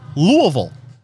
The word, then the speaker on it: Louisville: Next on the list, Louisville.